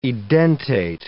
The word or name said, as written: edentate